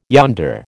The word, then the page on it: yonder: American English